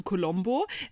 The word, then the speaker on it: Colombo: Genau, von Colombo zu Colombo. So spricht man es aus.